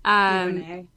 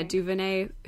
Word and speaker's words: DuVernay: Ava DuVernay, how do you say her name? Is that correct? I apologize. – DuVernay. – Yeah, DuVernay, who was the director of Selma, is apparently being courted by Marvel to direct one of their, quote, diverse superhero movies.